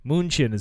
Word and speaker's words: München: Carlotta Liebenstein was a German countess who resided in Munich, or München, if you're from there. München is how they pronounce it.